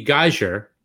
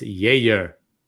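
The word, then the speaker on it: Jäger: It's spelled Geiger. – That's correct. – You'd think it'd be geyser. – You'd think it would. – You'd think, but no. No, it's Yeager.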